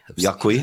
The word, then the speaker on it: Yaqui: Let me go with Jackie. – All right, Jackie B. Here comes your time. – Jackie, Q-U-I? – How do you say it? – It's a complicated way. – Yaqui? – It's Jackie.